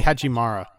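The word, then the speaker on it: Hachimura: But, Roy Hachimara. – That guy. Um. No, you actually need to say it. I, I oh, do. Oh, Roy Hachimara.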